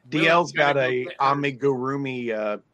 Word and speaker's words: amigurumi: And Cindy says that's pronounced Amigurumi. – Amigurumi.